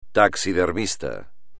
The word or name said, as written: taxidermista